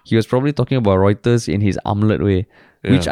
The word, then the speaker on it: Reuters: And amongst all the very well thought out comments and all, this comment was basically small matter, but did Harish pronounce Reuters as router? Isn't it pronounced as Reuters? – Yeah. – Then I was thinking like, fuck, did I say Reuters or Routers? – You said Routers, I think.